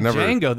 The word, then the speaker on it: Django: It's like Django. The J is silent.